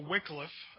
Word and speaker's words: Wycliffe: But before we start with Wycliffe, or Wycliffe, I think is the correct pronunciation, let's look at some terms that might be helpful as we go along.